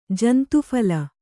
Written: ಜಂತುಫಲ

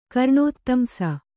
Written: ಕರ್ಣೋತ್ತಂಸ

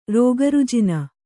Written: ರೋಗರುಜಿನ

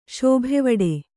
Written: ಶೋಭೆವಡೆ

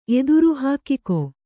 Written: ಎತ್ತರ

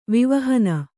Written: ವಿವಹನ

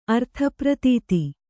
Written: ಅರ್ಥಪ್ರತೀತಿ

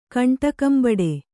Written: ಕಂಟಕಂಬಡೆ

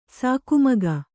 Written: ಸಾಕುಮಗ

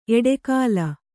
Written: ಎಡೆಗೋಲ್

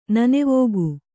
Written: ನನೆವೋಗು

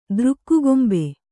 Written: ದೃಕ್ಕುಗೊಂಬೆ

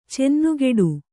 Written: ಚೆನ್ನುಗೆಡು

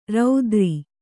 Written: ರಾತ್ರೆ

